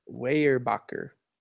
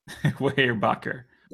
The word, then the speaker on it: Weyerbacher: How do you say it? – Roll the tape back. – Yeah. – Weyerbacher. – Weyerbacher. We'll go with that one.